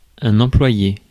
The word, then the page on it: employé: [ɑ̃.plwa.je]